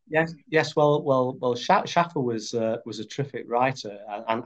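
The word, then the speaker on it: Shaffer: Schaffer, is that the right pronunciation? – Yes.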